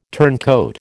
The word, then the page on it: turncoat: American English